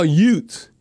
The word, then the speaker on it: Utes: The one thing about the Saints organization, we love Utah Utes. You know, I'm not pronouncing the TH because there's no TH.